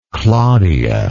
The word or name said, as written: claudia